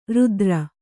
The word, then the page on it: ರಾತ್ರೆ: Kannada